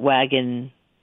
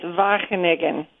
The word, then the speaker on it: Wageningen: And how do you say the university you're going to? – Oh, I probably won't hit it on the nose either, but it's Wageningen.